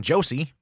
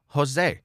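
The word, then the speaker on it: José: You don't call the person Josie, which is how we would pronounce that in English. If we read it like it was an English name, we pronounce it Jose.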